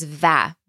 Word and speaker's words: و: So this can be pronounced either as او or as va. Both are used often. Va. But in conversation, usually it's heard as او.